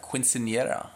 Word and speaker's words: quinceañera: They have quinceañera.